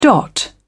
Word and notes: dot: British English

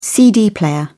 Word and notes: cd player: British English

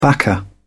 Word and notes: backer: British English

